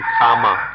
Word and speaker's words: kamma: But if we, we understand karma, kamma, karma is the Sanskrit pronunciation, kamma, the Pali pronunciation.